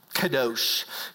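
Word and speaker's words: kadosh (קדוש): And in Hebrew culture, the term holy, it's pronounced kadosh. Kadosh means holy.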